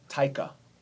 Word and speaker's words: Taika: Taika. – That's how you say his name? – Yeah, Taika.